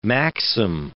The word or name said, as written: maxim